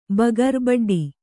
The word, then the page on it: ಬಗರ್ ಬಡ್ಡಿ: Kannada